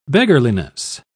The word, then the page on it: beggarliness: British English